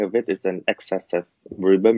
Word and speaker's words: Riba: I'm going to mispronounce them, right? Riba, Maisir, and Garar. Yeah. Did I pronounce that properly? – Yeah.